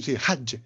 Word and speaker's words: Hajj: Lo pronuncio bene? – Sì, Hajj, il Hajj.